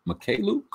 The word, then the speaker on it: Mykhailiuk: I think it was for Svi Mykhailiuk. I think is how you say his last name. – Well done. – Is that Mykhailiuk? Is that it? – Better than I would have guessed.